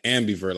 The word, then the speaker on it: ambivert: How do you say it? – Ambivert. Like, I think it's spelled A-M-B-I-V-E-R-T. Ambivert.